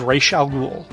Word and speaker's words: Ra's al Ghul: Highlights from this bold new era of Batman Nightcast include The Joker's Laughing Fish, The Saga of Ra's al Ghul.